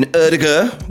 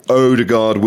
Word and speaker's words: Ødegaard: Yeah, I've pronounced it right. That's what he says. He's been telling us all how to pronounce his name. Although he did say Ødegaard will do. So maybe you can call him either.